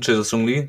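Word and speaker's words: Jae-sung Lee: Minute dann äh, kam, ich weiß, ich habe bei einer letzten Folgen gegen Mainz habe ich auch schon den Namen falsch ausgesprochen, Jason Lee, ähm, Chesung Chesung Lee kam dann für Ingwertsen.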